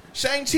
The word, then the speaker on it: Shang-Chi: Chang. Help me out. Disney. Chang Lee. Chang. – No idea. – Ten Rings movie. – Shang-Chi and the Legend of the Ten Rings.